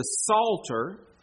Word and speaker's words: Psalter: The book of the Psalms, or you may hear it referred to as the Psalter. That's spelled the same way, P-S-A-L-T-E-R. I said the same way as the Psalms. The P is silent. So you've got P-S-A-L-M-S for Psalms. The Psalter is P-S-A-L-T-E-R.